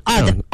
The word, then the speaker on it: odd: 对，大家发音特别注意啊，就特别短促有力 odd、而那个呢是 old，这是一个双元音 old。